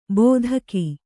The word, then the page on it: ಬೋಧಕಿ: bōdhaki